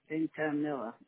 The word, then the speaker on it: Vintimilla: V-I-N-T-I-M-I-L-L-A. Ventimiglia. It's really exactly the way it sounds.